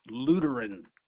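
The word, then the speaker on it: Luteran: It's L-U-T-E-R-A-N, pronounced Lutheran.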